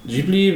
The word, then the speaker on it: Ghibli: Äh, ein Ghibli oder ein Ghibli bezeichnet einen heißen Sahara-Wüstenwind und ist ein, das ist ein italienischer Begriff, der ähm, arabischen Ursprungs ist. Das harte G kommt vom Italienischen, wie man das jetzt hier aussprechen mag, äh, sei jetzt mal dahingestellt.